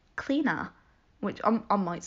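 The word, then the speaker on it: Clíodhna: So it's pronounced cleaner, apparently.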